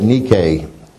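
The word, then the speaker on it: Nike (νίκη): But "overcometh" is from the Greek N-I-K-E, and the phonetic spelling of that tends to me that we should pronounce that Nike instead of Nike. So the shoes may be Nikes, but I think the word N-I-K-E in the Greek here, with what in context here, should be pronounced Nike.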